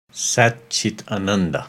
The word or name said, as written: Sat, Chit, Ananda